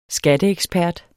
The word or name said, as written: skatteekspert